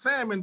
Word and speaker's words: salmon: It's pronounced salmon, but it got an L after the A.